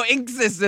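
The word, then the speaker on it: INXS: I was like, inks? Then they're like, no, inksis. And I was like, inksesis.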